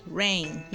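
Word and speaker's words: Reign: Como é que pronuncia isso? – Rain, isso. Toda vez que eu falar ele, eu vou botar para falar, ok, galera?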